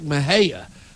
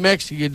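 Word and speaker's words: Mexia: It's like Mahia. Mahia is not even close to where it's spelled. When it's M-E-X-I-A, I thought it was that's the first thing I said was, where the heck is Mexi at? And they said, that ain't Mexica, dude.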